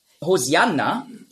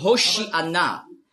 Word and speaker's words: Hosianna: Hosianna ist einfach die griechische Aussprache von Hoshi Anna. Hosianna, das ist die griechische Aussprache. Weil die Griechen, die haben im Alphabet kein Sch-Laut. Nur Z. Die Hebräer schon. Auf Hebräisch sagen Hoshi Anna. Aber Griechen können das nicht.